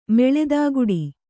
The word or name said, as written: ಮೆಳೆದಾಗುಡಿ